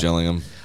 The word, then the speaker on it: Gillingham: Beat your bitter rivals, Gillingham, spelled Gillingham, call themselves the Gills, even though the town is pronounced Jill.